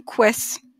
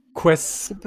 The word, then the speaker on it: Quess: Juste, j'aimerais bien qu'on prononce euh, mon prénom Quess. – Quess.